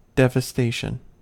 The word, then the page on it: devastation: American English